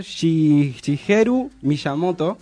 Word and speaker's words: Shigeru Miyamoto: Bueno, este juego fue creado por Shigeru Miyamoto.